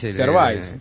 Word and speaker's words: Gervais: Es del amigo Ricky Gervais, ¿se pronuncia? ¿Cómo se pronuncia? – Ricky Gervais. – Gervais, ¿sí? – El de The Office.